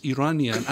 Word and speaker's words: Iranian: And by the way, the correct pronunciation is Iranian, and I'm not.